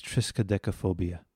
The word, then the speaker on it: triskaidekaphobia: It's triskaidekaphobia.